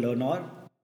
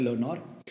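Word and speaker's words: Learnaur: we we are also co-founders at a content creation company called Learnor where it's it's all about learning. It's spelled as learn, L E A R N A U R R. Uh, the Hindi or means for more. So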